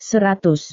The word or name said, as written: seratus